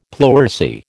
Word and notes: pleurisy: American English

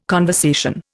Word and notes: conversation: Indian

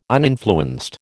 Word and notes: uninfluenced: American English

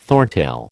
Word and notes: thorntail: American English

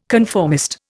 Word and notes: conformist: Indian

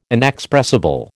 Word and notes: inexpressible: American English

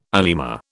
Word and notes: anima: British English

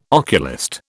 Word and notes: oculist: British English

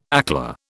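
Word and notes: éclair: British English

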